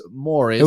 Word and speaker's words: Maurice: Not tomato, Maurice. Tomato. It's Morris. – It was Morris. It was spelled M-A-U-R-I-C-E. – Okay. But which he pronounced Maurice. – It exactly. But he pronounced it Morris.